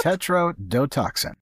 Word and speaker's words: tetrodotoxin: Tetrodotoxin.